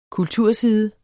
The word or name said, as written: kulturside